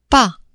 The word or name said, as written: pas